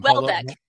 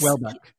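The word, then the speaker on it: Houellebecq: Welbeck. Michel Welbeck. Yes. – Welbeck.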